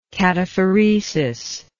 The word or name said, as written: cataphoretically